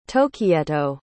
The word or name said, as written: tohkieto